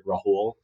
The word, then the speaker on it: Rahul: My handle is Real Estate Rahul. So real estate, and my name is spelled R-A-H-U-L.